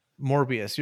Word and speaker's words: Morbius: and then Mobius I think comes out early next Morbius. Year say his name right. – Morbius, you're right.